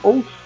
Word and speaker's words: Wolf: É assim a pronúncia? Wolf. – É, certa a resposta.